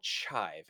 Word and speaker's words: chive: I wanted to point you out, not to embarrass you in our very first episode, but you've misspelled it and mispronounced it. It's called chive, and they're like little green onion type things that you sprinkle on top of a bunch of different food.